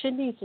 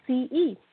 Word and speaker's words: Shaniece: It's Shanice, and it's spelled just like it's pronounced. My aunt named me, so it's Shanice. Shanice, because I'm her first niece.